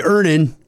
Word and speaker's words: Earnin: Earnin. – Earnin. – E-A-R-N-I-N is pronounced Earnin. I don't know how else you would pronounce it, but they really want it clear that that's how to pronounce it.